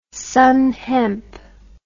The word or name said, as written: sann hemp